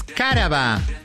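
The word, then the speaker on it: cáraba: Vamos a decir la primera palabra de esta mañana es caraba. – ¿Eh? ¿Cómo? – Caraba, no. Es drújula, es con acento en la A.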